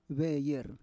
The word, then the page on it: veyer: Roon